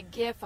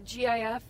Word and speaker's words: GIF: And a GIF, a hard G. You see the GIF, that's a hard G.